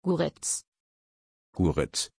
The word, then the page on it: guriz: German